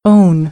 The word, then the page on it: own: American English